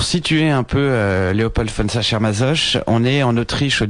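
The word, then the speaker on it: Sacher-Masoch: Et donc c'est adapté d'un texte de Léopold von Sachermassor, c'est ça, Oui, il y a plusieurs prononciations, mais Sachermassoche, oui.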